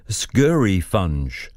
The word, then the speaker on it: scurryfunge: It is scurry funge. I'll spell it S-C-U-R-R-Y-F-U-N-G-E, scurry funge.